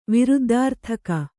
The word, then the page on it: ವಿರುದ್ದಾರ್ಥಕ: Kannada